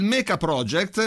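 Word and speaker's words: Mecha PRJT: Si pronuncia il Mecha Project, ma è scritto Mecha col CH, quindi M-E-C-H-A, e Project è scritto P-R-J-T, come se fosse il codice fiscale praticamente. Quindi il Mecha Project, questo è il nome della band.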